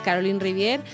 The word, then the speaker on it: Caroline Riviere: Nos vamos a acercar hasta San Pedro del Pinatar para hablar con la portavoz de Por un Mar Menor Vivo, que es Caroline Rivier. Eh, Caroline, buenas tardes. – Buenas tardes. – No sé si estoy pronunciando bien tu nombre. ¿Lo, lo, dices así? Vale. Sí.